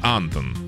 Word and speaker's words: Антон: На А ударение. Антон.